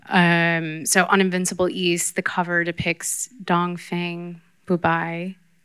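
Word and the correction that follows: Dongfang Bubai (東方不敗): right, Dongfang Bubai.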